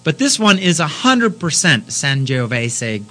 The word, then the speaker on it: Sangiovese: Sangiovese, that's how they Sangiovese.